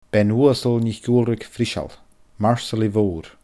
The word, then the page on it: Bean-Uasal NicUalraig-Friseal: Scottish Gaelic